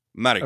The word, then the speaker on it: Mariko: But I think Mariko, I think you're going with the Spanish do the second to last syllable thing. – Right. – We're doing Japanese pronunciation. I'm pretty sure you're supposed to emphasize the first syllable.